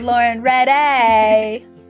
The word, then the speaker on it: Lauren Riddei: And we have had a pâté today with Lauren Riddei.